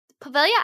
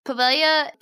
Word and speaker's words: Poveglia: This is an Italian island, but it's spelled P-O-V-E-G-L-I-A. Pretty sure it's Pavilion. Because you don't pronounce the G most of the time in words like that. Anyways, Pavilia Island, off the coast of Venice in Lido, Italy, is nicknamed Island of Ghosts due to its grisly past.